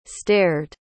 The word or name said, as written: stared